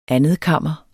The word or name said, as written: andetkammer